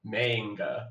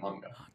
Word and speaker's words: manga: So it's not like manga would have to be like that may sound isn't how you pronounce the ma, it's ma. – So it's so, literally wrong the way I'm saying it. – Yeah, well, yeah. It's ma, n, and ga.